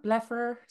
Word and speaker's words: blepharospasm: Also, sorry, we're witches because that TikTok you made about your, how do you pronounce blepharospasm? – Blepharospasm, yeah.